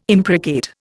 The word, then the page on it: imprecate: Indian